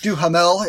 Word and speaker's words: Duhamel: Josh Duhamel.